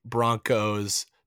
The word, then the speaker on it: Bronchos: Broncos, spelled Bronchos, but it's pronounced Broncos the same way you pronounce the NFL's Denver Broncos with a hard K sound.